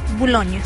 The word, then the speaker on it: Boulogne: Cecilia, no sé si lo dije bien. – Boulogne, sí.